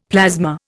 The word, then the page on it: plasma: Indian